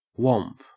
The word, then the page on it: whomp: Australian English